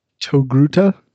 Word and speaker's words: Togruta: Togruta? – That's correct.